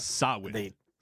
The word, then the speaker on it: Samhain: Samhain. Samhain is how you pronounce it. – Samhain. – Yes, even though there's an M and an H, several letters.